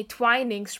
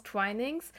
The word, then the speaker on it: Twinings: Nee, Twinings spricht man das aus, Twinings.